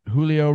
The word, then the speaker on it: Julio: Julio Rodriguez from the Seattle Mariners. I always forget that the Spanish is pronounced the J as the H.